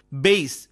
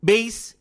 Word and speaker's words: bass: In this sentence, bass, B-A-S-S, bass, refers to a low musical sound. It is pronounced bass even though it is spelled as B-A-S-S. But consider, they caught a large bass in the lake. Here, bass refers to a type of fish, pronounced as bass. The spelling is same, but the pronunciation and meaning are different.